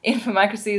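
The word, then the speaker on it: Infomocracy: Joining me today on Midnight in Karachi is writer Malka Older, whose book, let me get that right, Infomacracy? Is that how you say it? – Perfect. – Infomacracy is now out and has been lauded all over the place.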